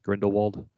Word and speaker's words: Grindelwald: I mean, he lost two prominent roles with Captain Jack Sparrow and Grindelwald. I said that right, correct? Grindelwald? – Yep.